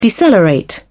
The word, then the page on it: decelerate: British English